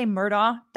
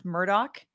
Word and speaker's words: Murdaugh: I want to say Murdaugh. That's how I want to pronounce it, but I have heard it pronounced Murdaugh. And apparently he goes by Alec, not Alex, even though he spells it A-L-E-X.